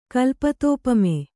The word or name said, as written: ಕಲ್ಪತೋಪಮೆ